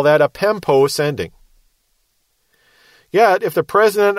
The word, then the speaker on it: pempo: Apostello is A-P-O-S-T-E-L-L-O. And you can put a line over that second O, that's a long O. Pempo is spelled P-E-M-P-O. Line over the O is a long O again.